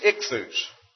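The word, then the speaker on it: ichthus (ἰχθύς): The Greek word for fish is ichthus, spelled with an iota, which is our equivalent to our English I. And then it has a chi or chi, which is an X that corresponds to a strong K.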